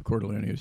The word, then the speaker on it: Cordileone: now the Archbishop of San Francisco, and I asked him how to pronounce his last name, uh, Cordleone, Cordleone, and he says, "I'm Italian. You pronounce every syllable, every syllable, every vowel."